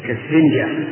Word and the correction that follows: إسفنجة: أنا أحكي هذا فيها همزة كالإسفنجة هكذا ننطق به.